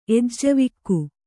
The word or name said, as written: ಎಜ್ಜವಿಕ್ಕು